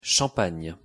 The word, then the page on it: champagne: French